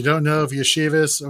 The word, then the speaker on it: Iosivas: Iosivas.